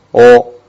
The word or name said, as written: wo